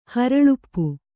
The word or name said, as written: ಹರಳುಪ್ಪು